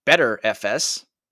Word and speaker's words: BTRFS: There is also BTRFS, which apparently is supposed to be pronounced better FS.